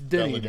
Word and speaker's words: Denis: V-U-E at the end, so it's Denis, though. It's Denis. – Denis. That's how the French say Dennis. – Yeah. – Denis.